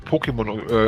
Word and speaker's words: Pokémon: Also es wird Pokémon ausgesprochen. Ich kenne so ein bisschen, weil ich habe ein paar Sachen davon gespielt.